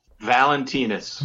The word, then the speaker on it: Valentinas: Valentinas.